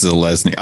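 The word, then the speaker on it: Zelazny: Dick, Roger Zelensky, Roger Zelezny, right? That's how you pronounce it. – I think it's Zelezny. I've always said Zelezny. I who knows? – Yeah, Zelezny.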